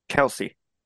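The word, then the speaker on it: Kelce: They will talk about her and her romance of this football player tight end, Khaleesi, I think. Is it pronounced Khaleesi, or Khalees? – Kelsey. – Okay, Kelsey.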